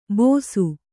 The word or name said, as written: ಬೋಸು